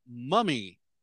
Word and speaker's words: Mumme: All right, so McMurray football coach Hal Mummy, but it's spelled M-U-M-M-E, but it's pronounced like mummy. Get it? – Oh, yeah, I see what you're doing here.